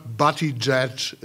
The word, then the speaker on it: Buttigieg: Aber Sie haben es hier gehört, Buttigieg ist die korrekte Aussprache. Jedenfalls sieht Pete Buttigieg es selbst so.